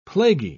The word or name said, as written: plaguey